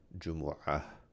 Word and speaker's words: Jumu'ah: In Arabic, it's probably more correctly pronounced Jumu'ah with the letter Ayn, which is not in English, so it sounds a little different.